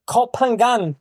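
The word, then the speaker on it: Ko Phangan: Und ähm, ja, jetzt haben sie einen Papa, der auf Kopangan, nee, wie spricht man das aus, richtig? Ja, Kopangan. Kopangan äh, lebt.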